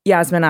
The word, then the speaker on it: Yasmine: You're saying it right when you say Yasmine, but the way I say it, it's so fast and run together, it sounds like Yasmine Akram. So it sounds like I'm saying Yasmine, but I'm actually saying Yasmine.